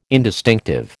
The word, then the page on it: indistinctive: American English